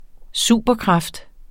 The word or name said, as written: superkraft